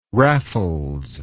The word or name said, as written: raffles